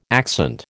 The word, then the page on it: accent: American English